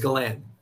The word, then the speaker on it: Galan: I mean, for a short last name like that, I've had so many different like pronunciations of it. I've had Galan, Galan, Galen. I don't even know other ones. Somebody really mentioned it. Yeah, but it's Galan.